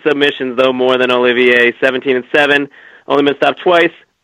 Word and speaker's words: Olivier: Well, Oliver, you know, we're talking about good nicknames. I think it's Olivier, by the way.